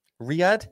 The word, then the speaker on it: Riyadh: Riyadh, Saudi Arabia, Riyadh.